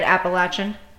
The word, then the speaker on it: Appalachian: That's how you say it, Appalachian? – Yes.